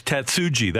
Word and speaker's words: Tatsuji: So I want to, it's Tatsuji, that part is the J we pronounce. Okay. – We pronounce the J.